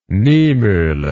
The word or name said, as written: Niemoeller